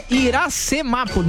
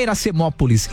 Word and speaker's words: Iracemápolis: Ó, o cara falando aqui, ó, é Iracemápolis, né? Não é Iracemópolis. Iracemápolis. Agora falei certo.